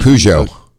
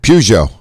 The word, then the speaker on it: Peugeot: P-E-U-G. – Peugeot. – What's that? – Peugeot.